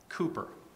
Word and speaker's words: Cowper: His name is spelled C-O-W-P-E-R, and so sometimes people call him William Cowper. That might be what you refer to him as, but I think he's pronounced Cooper.